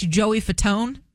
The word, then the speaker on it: Fatone: And if you watch Joey Fatone, that's how you say his name, right? Okay. I'm going to confirm that.